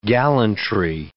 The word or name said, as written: gallantry